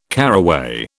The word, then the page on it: caraway: British English